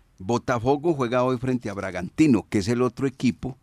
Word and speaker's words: Bragantino: El Bragantino. – Sí, así es, así se pronuncia.